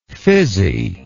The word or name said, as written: fizziness